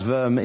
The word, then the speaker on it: Worms: A diet is simply a gathering, and worms, or it's pronounced verm, is a place in Germany.